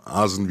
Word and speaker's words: Asen: Asen wie Nasen ohne N am Anfang.